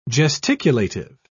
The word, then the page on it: gesticulative: British English